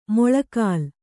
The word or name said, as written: ಮಳೆಗಾಲ